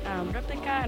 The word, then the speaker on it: RPLK: And then after that, you listen to Replika, which is letters are RPLK, but she's pronounced Replika, and her name is Carla Veronica Gonzalez Lopez, and she writes and produces her songs.